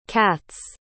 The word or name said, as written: katz